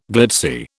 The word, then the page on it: glitzy: British English